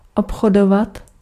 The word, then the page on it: commercer: France; [kɔ.mɛʁ.se]